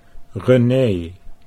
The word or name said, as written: Renee